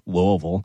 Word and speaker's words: Louisville: That's how you pronounce it, by the way, Louisville.